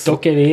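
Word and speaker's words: DokeV: Noe annet jeg har lyst til å prioritere er Doke V, er det det sånn det uttales? – Doke V, ja.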